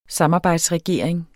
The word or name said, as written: samarbejdsregering